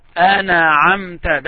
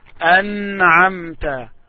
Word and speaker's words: أنعمت: So, the mistake that may someone make is to make قلقلة when they pronounce noon. صراط الذين أنعمت عليهم. Sometimes you hear it this way. صراط الذين أنعمت. أنعمت. That's a mistake. أنعمت. The correct way is أنعمت.